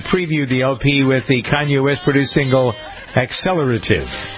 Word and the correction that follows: Accelerate: Accelerate. How did I say it? Accelerative? No, I think so. Accelerate.